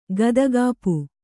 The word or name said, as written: ಗದಗಾಪು